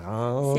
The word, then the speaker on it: Reims: Donc, on est passé par un studio associatif qui est basé à Reims, si je prononce bien. – À Reims.